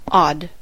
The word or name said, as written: odd